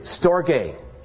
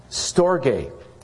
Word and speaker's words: storge: It's Greek. Storge. Storge is how it's pronounced.